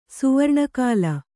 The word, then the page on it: ಸುವರ್ಣಕಾಲ: kannada